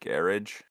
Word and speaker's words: garage: So are we going to address the fact that you said garage? – Yeah, because that's how it's pronounced. – Is that how it's pronounced? – Yeah, it's not. – Actually, they say that shit in the UK. – It's actually they say it. Yeah, it's oh, yo. Sure. Trust me. It's not garage.